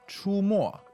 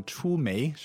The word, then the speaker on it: 出没: 出没， like appearing and disappearing。 – 对，看起来像出煤是吧？煤油的煤。 – 对，但是这是一个多音字，在这里呢我们要读没，那这个没就是隐藏起来。